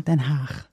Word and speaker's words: Den Haag: Das ist nämlich ein niederländischer Ort und das G in Niederländischen wird ausgesprochen. – Den Haag.